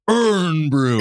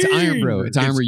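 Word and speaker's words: Irn-Bru: And the person I said before the show, joke of the day, gets this large bottle of Urn Brew. – It's Iron Brew. You guys are just saying it wrong on purpose.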